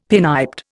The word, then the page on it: pinniped: Indian